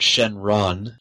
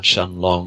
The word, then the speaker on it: Shenron: But they still have a wish, so they call out Shenron, not Shenlong. – Oh, in the book it's written as Shenlong. Two different separations. Yes, they definitely pronounce it with the R.